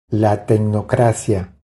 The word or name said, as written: La tecnocracia